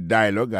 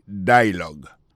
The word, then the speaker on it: dialogue: Le mot dialogue a gagné deux syllabes là-dedans. Daily dialogue, alors, il y a pesé plus sous premier syllabe là. Daily dialogue.